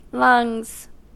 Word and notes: lungs: American English

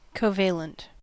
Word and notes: covalent: American English

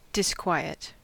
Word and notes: disquiet: American English

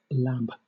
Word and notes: lab: British English

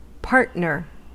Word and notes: partner: American English

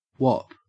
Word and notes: whop: Australian English

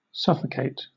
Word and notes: suffocate: British English

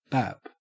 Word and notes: bap: Australian English

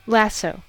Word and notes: lasso: American English

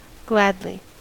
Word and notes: gladly: American English

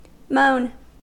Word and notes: moan: American English

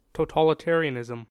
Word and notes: totalitarianism: American English

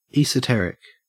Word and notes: esoteric: Australian English

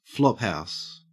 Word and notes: flophouse: Australian English

